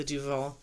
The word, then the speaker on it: Duvel: I think that's how you pronounce it. It's D-U-V-E-L. I say Duvel. – Looks like D-U-U-E-L. – That's a V.